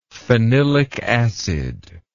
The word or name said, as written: phenylic acid